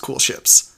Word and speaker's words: koelschips: It's spelled K-O-E-L-S-C-H-I-P-S, but it's pronounced Cool Ships.